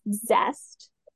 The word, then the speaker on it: ZST: They were all in spelled ZST, pronounced Zest, which was mostly classified as an amateur and semi-pro MMA promotion.